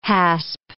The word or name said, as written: hasp